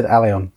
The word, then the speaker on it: Aellion: Well, I said Alien, they said Alion, so it's probably Alion. But it's spelled A-E-L-L-I-O-N, so... – M-O-E-O-S-E.